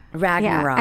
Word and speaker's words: Ragnarok: How do you say the name of that movie again? Thor Ragnarok. Ragnarok, yeah, and that's part of Norse mythology.